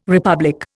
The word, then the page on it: republic: Indian